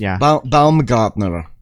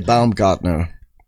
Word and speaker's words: Baumgartner: I don't know how to pronounce it. Bumgardner. I always laugh at that name. – Yeah, something like that. He's Austrian or German or something. Felix Baumgartner. – Okay. – I would say Baumgartner.